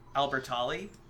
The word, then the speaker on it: Albertalli: This book was written by Becky Albertalli. I hope I'm saying that right. – Should be. – That's the way they said it in the audiobook.